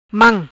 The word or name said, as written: Mam